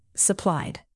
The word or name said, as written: supplied